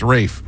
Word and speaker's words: Ralph: Pronounced Rafe, even though Rafe, it's spelled Ralph.